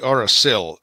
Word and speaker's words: Auracil: And you have an interesting way of pronouncing it. It's actually Auracil, S-I-L.